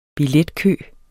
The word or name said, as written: billetkø